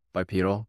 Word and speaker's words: bipedal: Did I say that right? Bipedal or bipedal? – Bipedal, yeah. – Bipedal, yeah, we're bipeds.